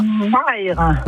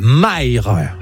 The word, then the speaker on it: Mayres: Pourtant, ça fait longtemps que je suis là, mais à chaque fois, je n'y arrive pas, alors je vais vous laisser le prononcer. Vous habitez à... – Maïr. – Et ça s'écrit ? – M-A-Y-R-E-S. – Ah, Maïr, quand même, hein ?